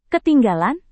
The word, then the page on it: ketinggalan: indonesian